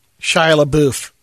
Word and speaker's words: Shia LaBeouf: FKA Twigs is suing Shia LaBeouf. Is that how you say it, or is it That's right.